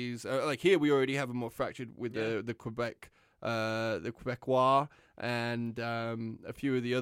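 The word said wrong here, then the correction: Québécois: You could just say the BQ for ease of pronunciation, but it's like Quebecois.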